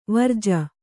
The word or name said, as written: ವಿರಜ